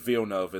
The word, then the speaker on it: Villeneuve: I believe Villeneuve is the correct pronunciation. – I have no idea what you just said or how you said it. So, anyways. – It's very French.